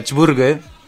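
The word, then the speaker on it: Čvrge: ¿Cómo, se llama la banda para que la eh, la banda van a tener que mm, poner alfabeto serbio, se pronuncia Chburge. ¿Qué? es complicado, son muchas consonantes y una sola vocal. Chburge, con el AC de circunflejo, B corta R, G, E.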